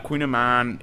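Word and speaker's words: kouign-amann: Queen Amman, it's pronounced queen as king and queen, and then Amman, A-M-A-double-N.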